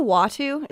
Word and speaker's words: Uatu: UATU. And because I've been reading it in comic books, I said it wrong in my head for 30 years. And I actually heard somebody say it. – Isn't it a Watu? – I think so. – Yeah, I'm pretty sure.